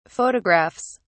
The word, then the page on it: photographs: British English